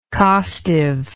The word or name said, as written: costive